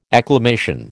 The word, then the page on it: acclamation: American English